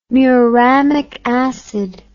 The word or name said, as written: muramic acid